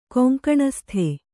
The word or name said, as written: ಕೊಂಕಣಸ್ಥ